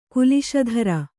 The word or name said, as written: ಕುಲಿಶಧರ